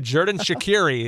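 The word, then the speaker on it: Xherdan Shaqiri: Jerdan Shaqiri is how we pronounce it here in the Americanized version as he joins the Chicago Fire.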